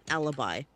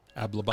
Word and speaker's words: alibi: I love the way they keep consistently mispronouncing alibi. – Ablibi.